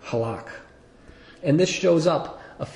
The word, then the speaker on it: halak: I'm not going to pronounce it right, but my best effort is halak, in English, H-A-L-A-K.